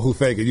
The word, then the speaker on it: Hufanga: Because I always mispronounce it. – No, Hufanga.